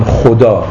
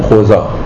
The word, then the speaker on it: خدا: بنابراین اونها نمی گفتن خدا در قدیم، می گفتن خوزا.